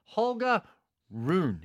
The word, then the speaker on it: Holger Rune: And we've got this new youngster, Holger Rune. Is that how you say it? – Yeah.